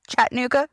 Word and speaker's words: Chattanooga: No, I don't know if I've been to Chattanooga either. How do you pronounce it? Chattanooga? – Chattanooga. – I don't really know. – You know, like Chattanooga choo-choo.